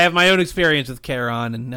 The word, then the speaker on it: Charon: No, Karen? I mean, Charon. – Charon.